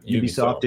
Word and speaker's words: Ubisoft: Ubisoft is shutting down online services for older games.